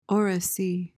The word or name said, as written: oracy